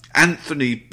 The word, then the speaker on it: Anthony: Anthony, pronounced the New York way, like in The Sopranos.